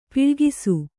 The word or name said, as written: ಪೊಳಕಿಸು